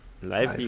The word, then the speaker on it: Lively: Leslie. – Lively, Lively. Ja, ich habe ihn mal Lively genannt.